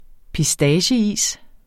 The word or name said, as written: pistacieis